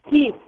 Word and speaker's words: Keith: Let's go to Jeith, truck driving in Tennessee, and I hope I got your name right. Did I? – It's Keith, right? K-E-I-T-H. – Keith, okay, we've got it as Jeith because of my friend here.